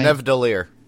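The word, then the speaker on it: Nidavellir: How do you say the name of that planet with the weapon guy? – Nevdalir. – You said it so great.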